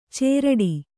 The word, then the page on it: ಚೇರಡಿ: cēraḍi